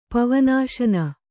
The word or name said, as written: ಪವನಾಶನ